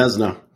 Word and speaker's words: Vezina: So I guess we'll move on to the Vezina Trophy. Is that how you say it, right? – Yep. Okay. Vezina.